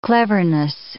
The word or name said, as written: cleverness